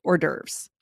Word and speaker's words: hors d'oeuvres: So the way we pronounce this, hors d'oeuvres. – Which the main thing that's tricky here is when you look at it spelled in French, hors d'oeuvre, it looks nothing like how we pronounce it.